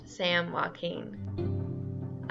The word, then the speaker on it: San Joaquin: Redding and Charlie Denmark have gone about 250 miles south to the mines on the Mariposa, a tributary of the San Joaquin River, pronounced Sam Joaquin.